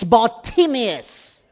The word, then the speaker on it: Bartimaeus: I don't know why we eliminate the middle part there. It's Bartimaeus.